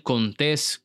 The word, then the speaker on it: Contés: Mi nombre es Miguel Contés con acento en la E y nos vemos en la próxima.